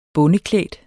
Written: bondeklædt

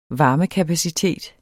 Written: varmekapacitet

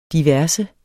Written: diverse

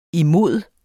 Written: imod

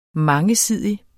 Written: mangesidig

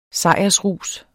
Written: sejrsrus

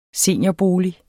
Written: seniorbolig

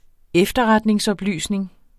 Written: efterretningsoplysning

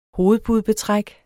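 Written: hovedpudebetræk

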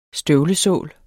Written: støvlesål

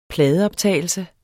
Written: pladeoptagelse

